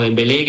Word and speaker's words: Dembélé: pues como Dembélé, que lleva acento y se pronuncia la Dembélé final, ¿no?